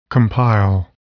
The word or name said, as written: compile